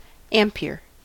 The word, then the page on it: ampere: American English